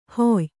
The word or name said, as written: ಹೊಯ್